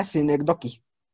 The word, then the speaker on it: Synecdoche: Bueno, hasta donde, recuerdo hasta donde recuerdo, eh, Charlie Cosman dijo que era Cinedoki. Synecdoche, ok. – Synecdoche, uh, eh, Nueva York.